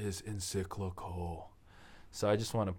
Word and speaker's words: encyclical: The Pope has come out with the encyclical. Did I say that right? – Yes.